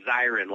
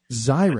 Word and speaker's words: Zirin: I should tell you that the last name is pronounced Zirin, like fire siren with a Z. – Zirin.